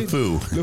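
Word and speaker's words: LeFou: You think this little, how do you say, Le Fou? What's the guy? How do you say? – Le Fou.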